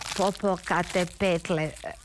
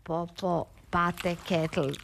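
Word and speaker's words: Popocatépetl: Slušajte, mučio je danima, reci popokate petle, reci popokate petle. Ona jadnica uporno govorila popopate ketl. Rekao je, glupača si, ne znaš izgovoriti ni popokate petle, a osim sve da imaš ove mizerno male grudi.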